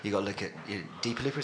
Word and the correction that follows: Dua Lipa: Is that how you say it? No, Dua Lipa.